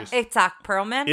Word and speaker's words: Itzhak Perlman: Itzhak Perlman. – Yeah, he say it again. – I think I'm saying it right. – No, you are. – Itzhak Perlman.